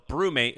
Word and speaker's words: Brumate: So Brewmate, it's spelled Brumate.